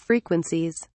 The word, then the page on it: frequencies: British English